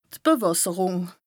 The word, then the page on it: d' Bewässerùng: Bas-Rhin; Strasbourg